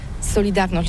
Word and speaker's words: Solidarność: Alors solidarité, en français on dit solidarnosc, mais ici à Gdansk on ne prononce pas solidarnosc.